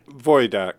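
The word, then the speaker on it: Wojdak: Vojdak. – Yeah, Vojdak. – Yeah, Vojdak. That would be the proper Polish pronunciation. And as we've chatted, many people know me as Wojak, but...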